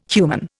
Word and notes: human: Indian

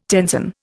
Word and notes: tenzon: Indian

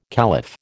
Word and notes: caliph: American English

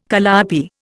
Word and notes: calliope: Indian

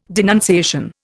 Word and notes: denunciation: Indian

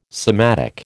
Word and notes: sematic: American English

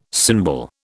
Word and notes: symbol: British English

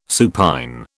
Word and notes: supine: British English

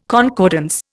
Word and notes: concordance: Indian

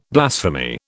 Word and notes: blasphemy: British English

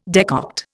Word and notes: decoct: Indian

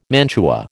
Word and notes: mantua: American English

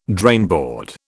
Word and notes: drainboard: British English

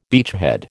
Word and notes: beachhead: American English

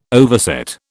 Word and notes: overset: British English

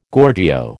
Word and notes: Gorgio: American English

